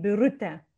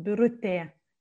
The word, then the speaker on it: Birutė: It's E with a dot, and we pronounce it E. I have it in my name, Birutė. You don't pronounce it really E like that in the word, but still it's E. It kind of goes up the sound, Birutė, E.